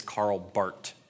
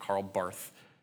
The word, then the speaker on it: Karl Barth: If you know anything about theology, you pronounce this as Karl Barth, not Karl Barth.